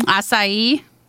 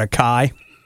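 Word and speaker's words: açaí: Acai. – I think that's a lie. I call it a Kai, you know, because I pronounce things my way.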